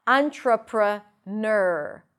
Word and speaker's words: entrepreneur: Entrepreneur, entrepreneur, the primary stress is on the last syllable.